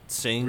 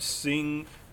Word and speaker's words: Tseng: well, in Crisis Core, you see it, that Tseng, or I think it's Song, the way it's pronounced.